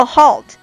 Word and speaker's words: halt: 这个 halt 啊，就是暂停、中断的意思。它的这个发音呢，在一个这个圆口 R 后边有一个 L，元音后边出现这个 L 呢，就应该发成一个舌侧音 u。